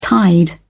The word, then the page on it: tide: British English